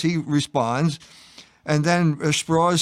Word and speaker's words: Strauss: And Strauss goes out of his way to say his name is pronounced Strauss because he comes from the South and he's an assimilated Jew.